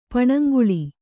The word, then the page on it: ಪಣಂಗುಳಿ: kannada